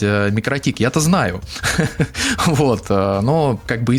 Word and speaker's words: Микротик: Оно переводится как «маленькая сеть», «микро-маленькая», «тикл-сети», поэтому, в принципе, по-латвийски По-латышски всем ударением получается микротик, ну, то есть не так, как вы все время ставите удаление на О, но так, как я сказал.